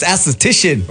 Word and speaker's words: aesthetician: And today is Anesthesian Day. Did I say that right? Yeah. – Yeah. No, it's aesthetician.